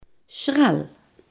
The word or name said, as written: zuring